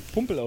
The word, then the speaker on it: Pumpel: Man könnte bei dem Namen natürlich auf den lustigen Gedanken kommen, wie viele Augsburg-Fans, was wir gerade schon gesehen haben, und einen Pümpel drunter posten. Aber er wird Pumpel ausgesprochen. Also, Matt Pumpel.